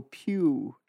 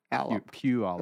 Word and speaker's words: Puyallup: Hold your nose and go Puyallup. – Puyallup. – That's how it's pronounced. Yeah, it's not how it's spelled.